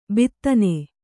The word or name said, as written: ಭೇದನ